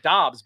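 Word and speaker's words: Doubs: And Pete, Romeo Dobbs, whose name is pronounced, I don't know if you saw this headline, it's pronounced Dobbs, by the way.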